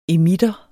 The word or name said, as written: emitter